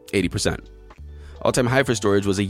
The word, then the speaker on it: Storj: It's Storj. That's how it's pronounced, it's, but it's spelled S T O R J.